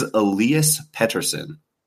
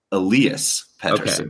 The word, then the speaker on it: Elias Pettersson: It is Elias Pettersson. Elias Pettersson.